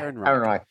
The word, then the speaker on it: Ehrenreich: Ehrenreich.